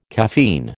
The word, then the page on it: caffeine: American English